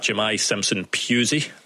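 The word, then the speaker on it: Jahmai Simpson-Pusey: Yeah, I did see a shot of Manchester City centre-back Jemai Simpson-Pusey, as I'm reliably informed it's pronounced, sitting in the stand.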